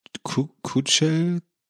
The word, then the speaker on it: Chuchel: Wikipedia sagt übrigens, dass es Chuchel, Kuchel ausgesprochen wird. Kuchel, glaube ich.